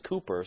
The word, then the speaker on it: Cowper: That's the way his name's pronounced over here. Cowper always said his name was Cooper.